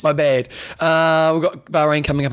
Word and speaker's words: Bahrain: They'll say Bahrain.